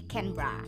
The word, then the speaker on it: Canberra: But the correct pronunciation is supposed to be Canberra.